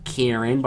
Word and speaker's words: Karen: My name is Karen, which everybody tells me is pronounced wrong. I just pronounce it Karen, but I sound like a total dick every time I do that.